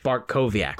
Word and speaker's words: Bartkowiak: Andrzej Bartkowiak.